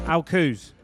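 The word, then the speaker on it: Al Quoz: Is it Alcuz or Alquaz? Alcuz, yeah.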